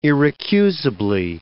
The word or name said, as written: irrecusably